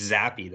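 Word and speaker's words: Zappe: It's Zappy though, not Zapp. – Zappy.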